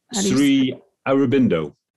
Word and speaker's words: Sri Aurobindo: So in your book, you quote, I'm going to say this wrong, Sri Aurobindo? Bindino? – Sri Aurobindo.